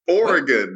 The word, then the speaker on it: Oregon: Oregon. What? There are three syllables. Oregon. – Oregon is what you Oregon. Play in church. – No. That's an organ, which has two syllables, not Oregon.